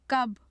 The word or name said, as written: kab